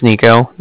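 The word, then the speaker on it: Niko: Again, my name is Nico. That's N-I-K-O. And then the Bank of Cardiff is spelled C-A-R-D-I-F-F, as in Frank Frank.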